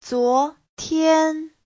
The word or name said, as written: zuo2tian1